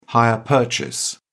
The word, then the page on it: hire purchase: British English